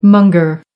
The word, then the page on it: monger: American English